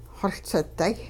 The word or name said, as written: xoLsiday